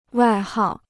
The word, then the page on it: 外号: wài hào